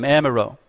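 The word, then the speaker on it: Amyraut: His name in French is Maurice Amiraud. That's how you say his name, Amiraud. Maurice. Most of us English people, we'd probably say Moises or Moise Amiraud. But it's supposed to be Maurice Amiraud.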